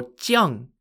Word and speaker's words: Jiang: This piece is called the Shuai if you're on the red side, or Jiang if you're on the black side. Shuai is spelt S-H-U-A-I, and Jiang is spelt J-I-A-N-G.